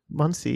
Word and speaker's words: Muncie: Am I pronouncing it right? – Muncie, yeah.